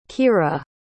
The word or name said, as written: kyrah